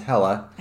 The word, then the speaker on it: Helle: First of all, her name is Hella. – I know. Like, and it's spelled H-E-L-L-E. Okay. So it's kind of like if you look at it, it would just be hell.